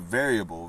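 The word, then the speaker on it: Veryable: It's called Variable, V-E-R-Y. It's spelled very able, but it's obviously pronounced variable.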